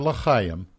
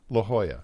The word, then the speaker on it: La Jolla: Not La Chaim, La Jolla. – Or La Jolla, as some people say. – Yes, if you went just with the pronunciation. – Just as long as you don't say La Jolly, right? – La Jolla.